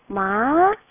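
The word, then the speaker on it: 마 (骂): 차승은 네, 누가 갑자기 때렸을 때, 아, 아! 이런 느낌으로. 네, 마! 네, 좀 강하고 좀 짧아요, 발음.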